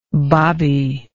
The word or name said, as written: Bobbie